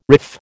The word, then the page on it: riff: American English